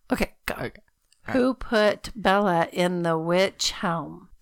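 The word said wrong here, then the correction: Wych Elm: Well, it's actually witch elm. I don't know why I kept saying witch helm. – Because it's spelled that way. – Well, I kept helm. in my mind, witch helm, but it's witch elm. – Like elm tree.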